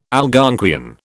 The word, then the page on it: Algonquian: British English